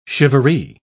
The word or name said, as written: shivaree